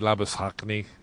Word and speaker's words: Labuschagne: I think that's how they, how he wants Australians to say his name, Labuschagne. It might be Labuschagne.